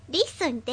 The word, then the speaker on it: listen: リスンって、なんか T が入るんでしたね。